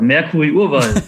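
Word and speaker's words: Mercuri Urval: Habe ich das äh, richtig ausgesprochen, das äh, schwedische Wort? – Ja, so als wärst du Muttersprachler. Mercury Urwal. Ne? Völlig ja. richtig.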